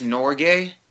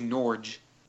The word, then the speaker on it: Norge: That's how you pronounce Norgay? – Yeah. – I always thought it was Norge. – No, it's Norgay. Having been to Norway, it's definitely Norgay.